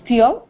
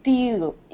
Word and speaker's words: steel: It's not steel, it's steel. Eel. – Eel. Steel. – You tend to pronounce A in the mid. Maybe that's how the pronunciation actually is in English. But the idea is to make the E elongate.